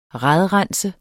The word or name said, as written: RADRENSER